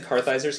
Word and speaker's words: Kartheiser: But, like, I mean, Vincent Kartheiser's character, is that how you say it correctly? – Yeah.